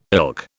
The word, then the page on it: ilk: British English